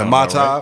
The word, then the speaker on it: Mai Tai: Mai Tai.